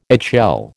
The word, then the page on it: eggshell: American English